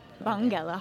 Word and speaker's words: Bongela: Is it Bongella? Bongella? – Yes. – Bongella. – Yeah. – Bongella. – It's a hard G. – Bongella Harji.